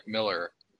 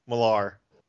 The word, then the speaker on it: Millar: Mark Miller. – Millar. He doesn't even pronounce it that way. I've heard him say his own name.